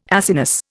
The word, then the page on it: Acinus: Indian